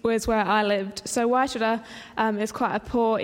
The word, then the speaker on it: Waitara: Okay, so this is up there is the town of Waitara. So that's how you say it, Waitara.